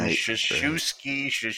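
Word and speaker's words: Krzyzewski: It's Krzanic, which is K-R-Z, and Coach K also has that K-R-Z, which is this Polish pronunciation, Shashewski.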